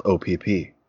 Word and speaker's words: Opp: So there's this town in Alabama called OPP, as it turns out. I guess the locals pronounce it OP.